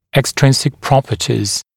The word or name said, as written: extrinsic properties